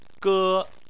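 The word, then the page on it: ge1: tone 1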